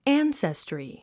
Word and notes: ancestry: ǽnsestri; アンセストリィ

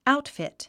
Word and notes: outfit: áutfìt; アウトフィット

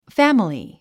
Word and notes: family: fǽməli; ファミリィ

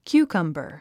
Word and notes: cucumber: kjúːkʌmbər; キューカンバァ

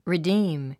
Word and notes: redeem: ridíːm; リディーム

